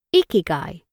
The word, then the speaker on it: ikigai: Ikigai is pronounced ikigai.